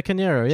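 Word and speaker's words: Carneiro: How do you say her surname? The one, the Mourinho physio that Mourinho... – Yeah, Eva Canero, yeah.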